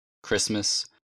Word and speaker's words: Christmas: Буква T не читается в некоторых словах. – Ballet, Christmas. – Ш в сочетаниях T плюс T-I-O-N, T-I-A-L, T-I-E-N-T.